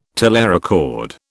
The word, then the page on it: telerecord: British English